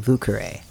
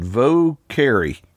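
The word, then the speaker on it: Vieux Carré: the most storied cocktail is the Vucare. – I'm glad that you pronounced that for me, because when I was reading it, I'm thinking, I wonder how you say that, Vocari. – Yes. Well, good try.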